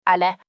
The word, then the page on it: aller: French